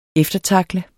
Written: eftertackle

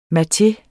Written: MATEEN

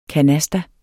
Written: canasta

